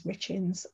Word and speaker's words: Richens: I spell it D E B I and my surname is Richens, R I C H E N S.